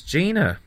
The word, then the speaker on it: Dina: There we are, that's Dina, or in Portuguese it's pronounced as Gina.